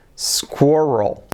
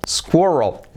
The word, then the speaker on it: squirrel: Escrito squirrel, pero se pronuncia, fijaos, squirrel, squirrel. Letra semiclave S, al principio no se dice S, sino S. Luego tenemos la letra clave I como es, square, y luego la clave, letra clave R, squirrel, squirrel, la letra clave L, oh my god.